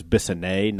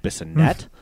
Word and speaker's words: Bissonnet: The misplaced affectation of his wife wanting to be known as Bissonnet, not Bissonnette. – Yeah. – Which no one would ever pronounce it that way, with the way it's spelled.